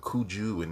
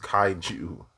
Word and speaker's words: kaiju: Some people call it kuju and kaiji and stuff like that. It's pronounced kaiju.